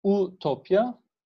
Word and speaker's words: utopya: E, onu olumsuzlayan bir şekilde o uk diye yazılıyor ama u diye okunuyor. Ve bunun ardına e eutopya. Bunu Türkçe'de vermek zor ama e eutopya ile utopya aynı şekilde okunan kelimeler.